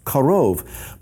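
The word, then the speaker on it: karov (קרוב): It's pronounced karov, but it's spelled, as you can see, very similarly.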